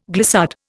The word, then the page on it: glissade: Indian